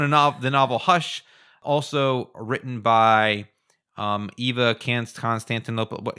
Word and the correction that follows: Konstantopoulos: Can you say that, Helen? – Konstantopoulos. – Sure, Konstantopoulos.